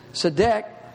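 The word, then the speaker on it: tzedek (צדק): The Hebrew word is T-Z-E-D-E-K. It's pronounced tzedek.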